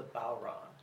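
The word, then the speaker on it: bodhrán: The actual name of it is the Balron. – Balron. – Balron, I guess, is the easiest way to pronounce it, which is, it's spelled B-O-D-H-R-A-N. You get Balron out of that. So, it's the Irish language and the pronunciation.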